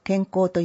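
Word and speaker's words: 健幸: 高齢者層を対象に介護予防や認知症予防、体力維持に向けた楽しいプログラムを定期的に提供し、龍ケ崎市民の健康づくりをお手伝いする施設として定着しつつあります。マイン健康サポートセンターを紹介します。健康の健に幸福の幸と書いて健康と読みます。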